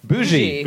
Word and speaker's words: bougie: You know, is it pronounced boogie? You know, B-O-U-G-I-E, the sort of things. Bougie.